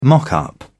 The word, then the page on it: mock-up: British English